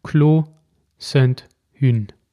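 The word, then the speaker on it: Clos Sainte-Hune: Aber der schreibt nicht Rosacker, Grand Cru auf seine Flasche, sondern Clos Saint-Hün. Hün. Das H spricht man ja nicht, habe ich, hab ich mir sagen lassen.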